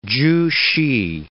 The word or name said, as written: Chu Hsi